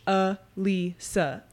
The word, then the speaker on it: Alyssa: So my website is U H L E E S U H A Lisa.